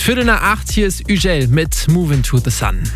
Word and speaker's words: Hugel: Wobei ich hier ganz ehrlich sagen muss, die ersten zwei Wochen war ich so ein bisschen mehr damit beschäftigt, herauszufinden, wie man eigentlich den Sänger da ausspricht. Hugel, Hügel, Hugel. Und dann habe ich irgendwann gecheckt, nee, das Ganze ist französisch und da spricht man dann einfach mal Hugel aus.